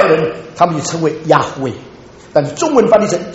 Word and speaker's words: Yahweh: 耶和华对我主说，耶和华 JYHWH，在希伯来文应当问念成 Yahweh， Yahweh 翻译成中文的时候 Jehovah，耶和华。